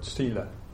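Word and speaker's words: sīla: sila. You'll hear some people pronounce it sila with a weird accent, but it is, kind of depends on where you come from. Sila is how I say it.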